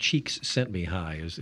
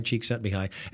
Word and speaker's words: Csikszentmihalyi: A previous author had put in her book that, I think Csikszentmihalyi is the way to say it. So it works. Mihai Csikszentmihalyi.